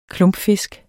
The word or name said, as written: klumpfisk